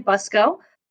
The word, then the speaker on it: Buxco: My name is Natalie Buxco. The C isn't pronounced.